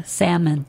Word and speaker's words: salmon: Salmon. – Do you say almond or do you say almond? – Almond. – These two popular foods are tricky because the L is silent in both.